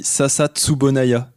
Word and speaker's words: Sasa Tsubunaya: c'est une plante et ça se dit Sasa Tsubunaya.